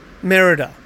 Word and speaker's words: Merida: how do you pronounce her name? Merida? – Merida? – Merida. – Yeah.